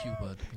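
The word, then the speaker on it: Q*bert: Klassiker gibt es halt nur einen, das ist dieser Quibert. Ich hoffe, der wird das ausgesprochen. – Quibert. – Ja. Quibert. Q-Bert.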